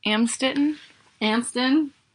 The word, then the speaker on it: Amstetten: He was born in April 9th, 1935 in, how do you say that? – Amstetten.